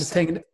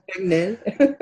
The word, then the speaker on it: Tegnell: We've had Tegnell. – Tegnell.